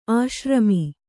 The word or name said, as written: ಆಶ್ರಮಿ